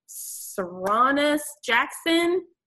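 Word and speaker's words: Sarunas Jackson: It features Lauren London and Saronis Jackson. I think I've just butchered your name. – No, that's how you say his name.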